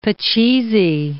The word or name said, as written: pachisi